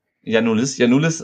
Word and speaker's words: Janulis: Der Spieler, den Andreas nicht mag, wie wird er ausgesprochen? – Janulis,